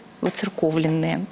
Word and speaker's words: воцерковленные: По сравнению с человеком, который до церкви еще совсем даже не дошел, вот мы, наверное, люди воцерковленные. Вот так правильно говорить, друзья. Вы не удивляйтесь, пожалуйста, такому ударению в этом слове.